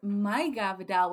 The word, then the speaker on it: Maiga Vidal: Name's Maiga Vidal, which is short for Margarita, like a margarita, which is very, very funny. But it's spelled M-A-I-G-A-V-I-D-A-L.